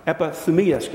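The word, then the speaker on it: Epithumia (ἐπιθυμία): It's the word epimetheia. Make sure I'm saying that right. Epithumia.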